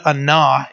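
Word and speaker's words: ana (עָנָה): So the word anah is the Hebrew word that was used, and it's spelled A-N-A, but it's pronounced A-W-N-A-W, ana, and it means to afflict, to humble, or to force.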